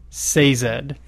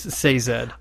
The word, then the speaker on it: Sazed: It's been Sazed in my head, but according to this, it's Sazed.